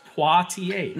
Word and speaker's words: Poitier: Poitier. It's actually pronounced Poitier.